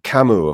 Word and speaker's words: Camus: Camus, C-A-M-U, currently accepted medical use. Camus, of course, spelled his name C-A-M-U-S, as in French, the S is silent.